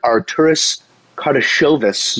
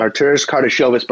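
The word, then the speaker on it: Artūras Karnišovas: Not only have they agreed to terms with new general manager Arturis Kardashevis. – You pronounced that one right there. – Beautiful. – Arturis Kardashevis, but from now on we will call him AK.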